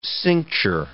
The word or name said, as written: cincture